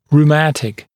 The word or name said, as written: rheumatic